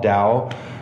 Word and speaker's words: DAOU: DAOU, did I say that right? – I think so. DAOU yeah.